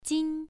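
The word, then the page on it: jin: tone 1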